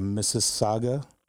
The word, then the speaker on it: Mississauga: Now, Coach, how were you introduced to the game of basketball coming out of Mississauga, Ontario? Did I get that right? – Correct. Yes. Good pronunciation.